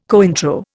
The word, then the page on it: Cointreau: Indian